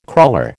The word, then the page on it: crawler: American English